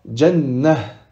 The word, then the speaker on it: Jannah: Si on s'arrête sur ce mot-là, on dira Jannah. Jannah.